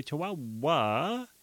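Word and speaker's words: Chiwawa: It's called Chihuahua, but it's spelt weird. It's C-H-I-W-A-W-A, Chihuahua.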